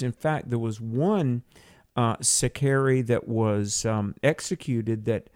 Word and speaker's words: Sicarii: It was written around 75 A.D., and Josephus mentions, and I'm probably not going to pronounce this correctly, a group called the Sicarii, S-I-C-A-R-I-I, Sicarii or Sicarii, I'm not sure.